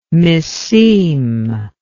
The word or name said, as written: misseem